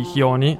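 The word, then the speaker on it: Jiony: La scena elettronica sudamericana ultimamente è molto interessante, lo abbiamo appena ascoltato attraverso questo pezzo di Chioni. Tra l'altro vi consiglio di andare sulla sua pagina di Facebook, si scrive J-I-O-N-Y, c'è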